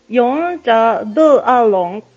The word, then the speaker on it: 勇者斗恶龙: 勇者斗恶龙と言います。